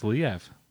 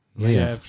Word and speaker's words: Liev: Pronounced Liev. Liev Schreiber. – It's actually called Liev.